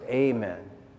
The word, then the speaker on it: amen: Hebrew, which is read, by the way, from right to left, amen. Some pronounce it amen.